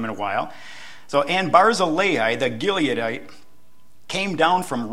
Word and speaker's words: Barzillai: How's that pronounced? Huh? Anybody else? It says in this one, Barzillai. Barzillai. All right. So I do still have one of those where maybe it's wrong, but it does have the pronunciations in there. So I'm going to be calling him Barzillai.